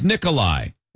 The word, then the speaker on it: Nikolai: My given name is Nikolai. It's pronounced the same way as Nicholas, but with a long I at the end.